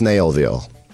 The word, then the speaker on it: Snellville: Or Snell, but it's pronounced Snailville. But it's Snellville, S-N-E-L-L.